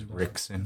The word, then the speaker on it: Rickson: And it was Hickson, but I didn't know how to even say his name properly. – And it was Rickson. – Rickson. Yeah. I just did not, you know, I was like, it's an R, you know? – Yeah.